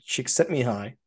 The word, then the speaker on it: Csikszentmihalyi: I know there's Mihai Csikszentmihalyi.